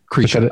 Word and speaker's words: Krisha: I think it's pronounced Krisha. – Krisha. Okay. – It's an I, but it's like an E sound. Krisha. Yeah. I think is how they say it.